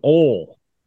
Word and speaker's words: oil: And then the last one is oil. Oil is one syllable. – Oil. – Oil. – Oil. – There you go. – That's oil. That's like Ole Miss. – No, oil. Like, I'm going to put oil on the pan.